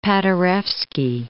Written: Paderewski

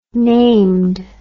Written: named

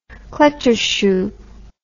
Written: kletterschuh

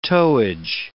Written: towage

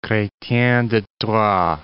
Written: Chretien de Troyes

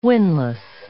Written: winless

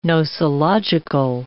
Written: nosological

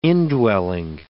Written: indwelling

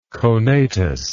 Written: conatus

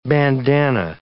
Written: bandana

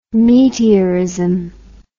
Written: meteorism

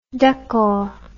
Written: daccord

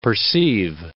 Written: perceive

